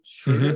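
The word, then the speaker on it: Schröder: Schroeder.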